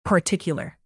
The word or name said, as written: particular